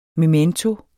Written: MEMENTOERNE

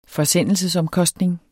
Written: forsendelsesomkostning

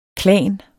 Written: klan

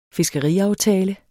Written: fiskeriaftale

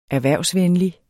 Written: erhvervsvenlig

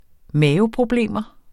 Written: maveproblemer

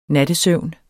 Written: nattesøvn

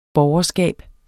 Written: borgerskab

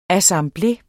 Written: assemblé

